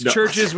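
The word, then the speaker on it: Chvrches: Have you ever seen Churches, which is C-H-V-R? Anyway, it's Churches with a V. And I was like, oh, Chavirches. And they're like, what are you fucking stupid? It's Churches.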